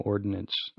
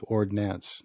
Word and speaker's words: Ordnance: Mayhem Ordinance. – Ah. – Spelled without an I, which means weapons. Ordnance, not ordinance, which is a law.